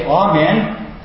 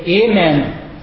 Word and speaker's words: amen: Well, this might not seem like much of an issue here, but you know, in some circles it is an issue, because there's two pronunciations for the word. We either say amen, or we say amen.